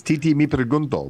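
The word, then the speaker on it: Tití Me Preguntó: It's Titi Mi Pregunto, Bad Bunny.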